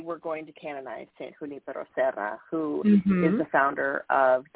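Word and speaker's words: Junípero Serra: I'm like, okay, you break it down. It's like, Junipero Serra. That's how you say it, Junipero Serra.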